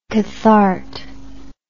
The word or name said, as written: cathart